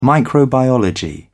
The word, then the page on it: microbiology: British English